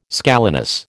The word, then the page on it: scalenus: American English